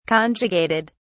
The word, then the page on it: conjugated: British English